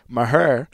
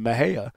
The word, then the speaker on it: Mexia: So Maher is a way of saying it. Mexia is another way of saying it. And Mahia, you know, is the I guess the correct enunciation of it.